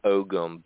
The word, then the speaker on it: Ogham: You get the inscribed stones and Ogun, or how Maria does the official pronunciation of Ogham, but you found that kind of writing.